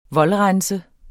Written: voldrense

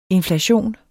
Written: INFLATIONENS

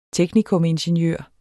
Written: teknikumingeniør

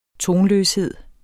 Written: tonløshed

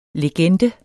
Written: legende

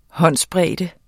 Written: håndsbred

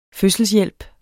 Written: fødselshjælp

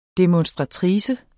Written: demonstratrice

